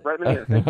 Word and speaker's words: Brett Manire: Brett Manire.